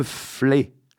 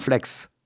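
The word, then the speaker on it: Fleix: En ce qui me concerne, je participerai dimanche prochain 20 novembre dans la commune de Le Flé. On prononce Flé et non Flex. Quand on y revient, c'est donc le reflet et non le réflexe.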